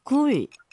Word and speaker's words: 굴: 약간 발음이 귤처럼 들리긴 했는데, 굴이죠? 예, 예.